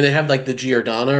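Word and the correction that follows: giardiniera: or whatever the hell you pronounce that in Chicago. I live here, but I don't know how to pronounce that. But with Jardinere? – Sure, Jardinere. – It's Jardinere, yeah.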